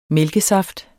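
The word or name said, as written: MÆLKESAFT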